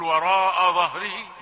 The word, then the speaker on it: وراء ظهره: همزة رقيقة والظاء بعدها مفخمة. هتقول وراء ظهره. لا مش اظا. لا الهمزة رقيقة فلماذا تنطقها بهذه الفخامة. وراء ظهره كذلك الهاء تأتي مرققة لا تؤثر عليها الظاء فتقول ظهره.